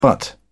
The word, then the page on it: butt: British English